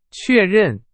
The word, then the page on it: 确认: Chinese